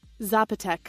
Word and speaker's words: Zapotec: Zapotec? I'm not saying that right. I have never heard it. Indigenous Mexican language. Indigenous pre-Columbian civilization that flourished in the Valley of Oaxaca in Zapotec.